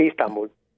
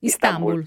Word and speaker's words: Istanbul: Sì, Istanbul. – Istanbul, dici? Con l'accento sulla I? – Istanbul.